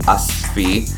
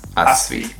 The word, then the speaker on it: Asfi: I mean, the closest pronunciation would be Asfi, as in us as in like, you know, we and us, and then fi, F-E-E. That's the closest pronunciation. Asfi.